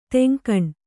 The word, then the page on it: ತೆಂಕಣ: teŋkaṇa